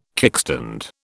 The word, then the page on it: kickstand: British English